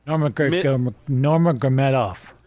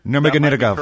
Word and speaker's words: Nurmagomedov: Nurmagomedov. – Nurmagomedov.